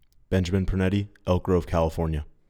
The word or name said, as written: Ben Pernetti